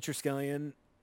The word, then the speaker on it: Triskelion: Dude, seeing the Triskelion, that's how you pronounce it, right? – Yeah, I think.